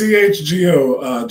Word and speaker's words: CHGO: Is it phonetically just Chicago or is it CHGO? – It's CHGO, Janice told me, because I asked her the same thing on Friday night.